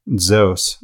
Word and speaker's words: Zeus: So another example, if we take Zeus and we pronounce it as an ancient Greek, Zeus, where there's, if you could just imagine a D in front of the Z kind of stuck to it, right?